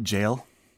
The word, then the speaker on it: Yale: I love how Germans call it jail. – They call Yale University jail? – Because of the pronunciation of the Y and the J in German.